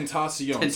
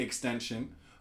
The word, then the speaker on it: XXXTentacion: Like, Tentacion. So people say extension. Those people... – Extension, I'm wrong. – There it is. but I don't know if it's XXXTentacion or XXXTentacion.